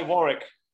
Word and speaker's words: Warwick: I love how you're correcting me on your incorrect No, but Warwick Davis was telling me how to pronounce there. He's like, it's Warwick, or it's Warwick, and they're like, Warwick. He's like, no, drop the W. They're like, Orwick. He's like, no, not the first W.